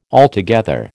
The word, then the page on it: altogether: American English